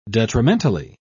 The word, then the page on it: detrimentally: British English